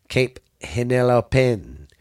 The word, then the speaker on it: Cape Henlopen: In 1825, Congress authorized the construction of the breakwater at the mouth of the Delaware Bay off Cape Henlop. I'm sorry, I said that wrong. Cape Henlopen.